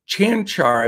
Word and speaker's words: Čančar: Uh, uh Kanchar has been uh, Čančar, I believe it's pronounced.